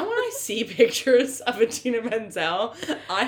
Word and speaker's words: Idina: Like, even if you're not familiar with Idina Menzel, you can look and... read the name and see that it's not Adele Dazeem. – It's Idina. Like, it's not, like, a hard name to pronounce.